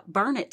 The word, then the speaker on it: Burnet: Our last son had went off to college, and so he, my husband, was working every other week in uh, Burnet, Texas. They say Burnet, darn it, gotta learn it. because I said Burnett, and they were like immediately uh, taught me the correct way to pronounce that.